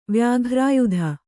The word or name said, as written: ವ್ಯಾಘ್ರಾಯುಧ